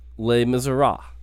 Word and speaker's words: Les Miserables: We got things, and then a review of Les Miserables. That's how you pronounce that, correct? – Sure.